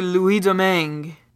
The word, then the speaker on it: Louis Domingue: Is it Louis Domingue? – It's actually Louis Domingue.